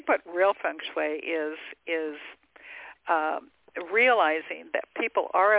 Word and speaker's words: feng shui: It's feng shui, like F-U-N-G, feng shui.